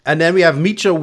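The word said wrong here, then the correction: Mischa: Am I saying his name right? – I think it's Misha.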